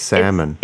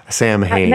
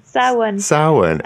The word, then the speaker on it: Samhain: Samhain. It's salmon. It's Samhain. No, that's Sam. my American butchering. Samhain. – Samhain. – Samhain. Oh. – And that's how you pronounce it.